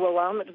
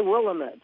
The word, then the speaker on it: Willamette: Well, you would say Willamette, but it's Willamette. We pronounce it up here.